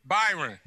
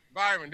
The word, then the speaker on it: Byron: We will begin today's media availability with Tampa Bay Buccaneers coach Brian Leftwich. – Byron. Byron, there we go.